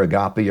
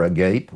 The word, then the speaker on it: agape: It's either agape or agape, whichever you look at it.